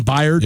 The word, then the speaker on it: Byard: Kevin, it's spelled like Bird, but I don't think his last name, Kevin Byard.